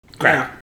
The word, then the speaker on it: craic: It's spelled C-R-A-I-C. – Crack. Yeah. I was never really sure. Gaelic is weird.